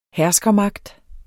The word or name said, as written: herskermagt